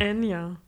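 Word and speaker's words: Anya: Ich bin ja auch ein großer Ann Taylor Joy Fan. Ann, ne, was habe ich denn schon wieder falsch gesagt? – Enya. Enya Taylor Oder Joy. Anja.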